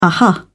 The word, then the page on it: uh-huh: British English